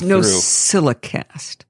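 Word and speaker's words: NosillaCast: By the way, it's NoZillaCast. When you say it with a Z, people can't find it. – That's a good point, because it's Allison spelled backwards. – It doesn't rhyme with Mozilla.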